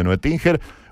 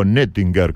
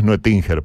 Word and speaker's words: Noetinger: Les mando un abrazo si hay alguien escuchando de Noetinger, o Noetinger, como se pronunciaría en alemán. Pero bueno, es Noetinger para nosotros.